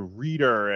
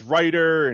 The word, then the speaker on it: Reiter: And then with the Chiefs, like I said, kind of similar, you know, Andrew Wiley at left guard and Andrew Reeder at center, your guy. Is it Reeder or Reiter? – I would assume it's Reiter, but I assume Mike Rice was Mike Rice because that's how it's spelled.